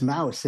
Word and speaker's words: Mous: And also, am I yeah, pronouncing that brand right? Yeah, it's Mous.